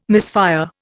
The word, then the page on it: misfire: Indian